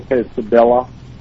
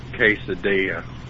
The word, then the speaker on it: quesadilla: Would you like to try a double quesadilla? – It's pronounced quesadilla.